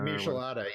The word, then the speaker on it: Michelada: I can never pronounce that. The Michelina. – Michelada. – Whatever.